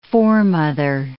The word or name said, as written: foremother